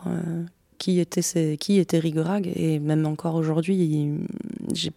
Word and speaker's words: Riga-Raga: Alors moi j'aurais prononcé bêtement Rigaraga parce que ça s'écrit comme ça, mais tu viens de me dire qu'on prononce bien Rigorag.